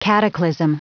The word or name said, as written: cataclysm